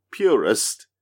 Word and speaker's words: Poo:Rest: mean, it's supposed to spell purist, but it's spelled poo, as in P-U, then the colon, and then rest.